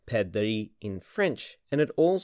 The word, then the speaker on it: perdrix: Now, in French, partridge are called perdrix. Excellent pronunciation there. That's perdrix, spelt P-E-R-D-R-I-X.